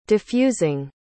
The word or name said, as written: defusing